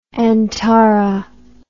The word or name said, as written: antara